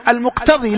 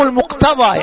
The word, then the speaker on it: المقتضى: إذن نحن لا نقول عموم المقتضي، لا، وهذا يخطئ كثير من الطلاب، ينطقها بوزن اسم الفاعل المقتضي وهذا خطأ. لا، الصحيح تقول عموم أو دلالة الاقتضاء، أو تقول عموم المقتضى، يعني ما اقتضاه الكلام، واحتاج إليه الكلام ليكون الفهم صحيح.